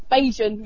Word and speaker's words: Bajan: We've got, over at the moment, we've got a trio of Barbadians. I think that's how you say it. They're from Barbados. – Yeah, Barbadians. – Bajan, I think. – Bajan, yeah.